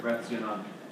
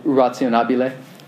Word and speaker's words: rationabile: Forgive the pronunciation, but the Latin word rationum. – Rationabile.